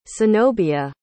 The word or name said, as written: cenobia